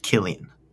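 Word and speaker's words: Cillian: It's an Irish name, and it's pronounced Killian.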